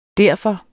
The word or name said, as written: derfor